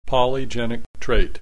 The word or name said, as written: polygenic trait